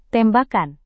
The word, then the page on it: tembakan: indonesian